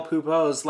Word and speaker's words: Pupo: In Portuguese, the final O, the final letter O, is a word, in a word, is almost always pronounced like the U in Pupo, giving the correct pronunciation for Miguel's last name the same sound as the word poo-poo in English.